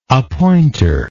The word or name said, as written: appointor